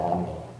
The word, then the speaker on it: AMLO: So a lot of people call him Obrador, which literally means worker, or more affectionately, his followers call him AMLO, which is the way you pronounce A-M-L-O, he's got two first names and two last names.